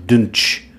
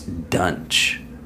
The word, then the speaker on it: Duntsch: D-U-N-T-S-C-H. Dunch. Dunch? He's probably one of those people who would correct you if you said it wrong. – Probably. – It's Dunch.